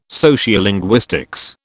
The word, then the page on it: sociolinguistics: British English